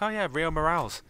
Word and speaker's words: Morales: I've just been mispronouncing it this entire time. But what? – Anyway, I'll say Morales from now on.